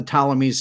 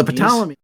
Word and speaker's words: Ptolemy: Okay, these are the Ptolemies spelled with a P in the front, right? – Yeah. – Right. Okay, so this was like a long, like pterodactyl, right? Or Ptarmigan. So the Ptolemy.